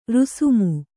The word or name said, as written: ರಾಸ್ಮೆ